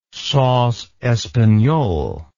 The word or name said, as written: sauce espagnole